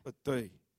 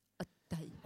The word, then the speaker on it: Auteuil: Auteuil. – Auteuil.